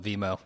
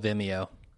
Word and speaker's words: Vimeo: That's not called Vimeo. It's called Vimeo.